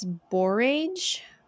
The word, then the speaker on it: borage: but I think it's borage or borage, B-O-R-A-G-E. I know people always borage. – Say, I'm a teacher. The silent E makes the A say its name, but that's not always true.